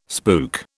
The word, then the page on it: spook: British English